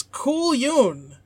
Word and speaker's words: cullion: And the word for this week's Marmalade Watch is Cool Yoon. – Cool Yoon? – It says it's pronounced... – It sounds like... – Yeah. It's spelled C-U-L-L-I-O-N. – Okay. – But it says pronounced K-U-L-Y-U-N.